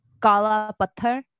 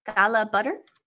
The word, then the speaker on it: Kalapatar: How do you say that again? – Kalapatar. It's like a Punjabi word. – Kala butter? – Pretty close. Yeah, that's fine.